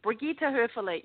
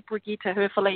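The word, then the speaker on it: Brigitta Hoeferle: Brigitte Höfele. You spell it just like you sound it out. It's really simple. B-R-I-G-I-T-T-A, last name H-O-E-F-E-R-L-E, Brigitte Höfele.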